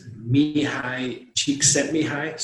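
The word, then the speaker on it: Mihály Csíkszentmihályi: And it's Mihai Csikszentmihalyi.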